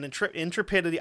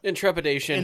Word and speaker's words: intrepidity: For conspicuous gallantry and intrepidity. I just said that fucking word not 10 minutes ago. You'd think I'd know how to say it by now. – Intrepidation.